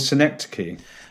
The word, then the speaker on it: Synecdoche: So it could be, you know, your favorite piece of music, you know, like you, I mean, like the film you've chosen, Syndosh, is that right? Am I pronouncing it right? – I think it's called Synecdoche.